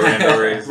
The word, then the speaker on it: randoris: And so I've definitely seen a lot of benefit to doing mob katas or randoris. Mm-hmm. Did I say that right? – Yeah, randoris.